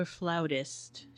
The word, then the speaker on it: flautist: We're back with is it flutist or flautist, Cheryl? How do you... – Either way. – Either way, there isn't a preference.